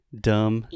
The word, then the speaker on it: dumb: Dumb. – You are dumb dumb. – D U M B. We do not pronounce the B.